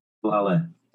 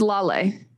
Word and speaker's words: Lale: Is Lale. – Really? – Yeah. – It literally is spelled like Lael. – I know. But, I know. They're not in America. It's okay. – Yes, it's Slovak.